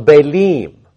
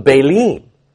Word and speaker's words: Baalim: What God is meaning by this is that the Israelites, the northern kingdom in this case, has gone after the Baals, the Baalim. That's the plural. The I am sound at the end, that's the plural. The Baalim, the harsh taskmaster idols.